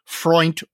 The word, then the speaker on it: Freund: Ken Freund, who I would not have known how to pronounce his last name other than he said Freund rhymes with joint.